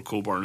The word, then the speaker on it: Cockburn: C-O-C-K-B-U-R-N. It's never pronounced Cockburn because people don't want to say it here that pronounced. They go for Coburn as like some weird middle alternative, because this is the same thing with Olivia Wilde, because Olivia Wilde's actual last name is Coburn.